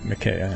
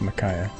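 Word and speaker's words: Makaya: I was corrected last night when I heard the announcer at the Vanguard say his name correctly, and then it was I was double corrected when he said his own name correctly, and I've been saying it Micaiah, and now it's Micaiah for here on out.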